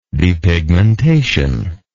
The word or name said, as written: depigment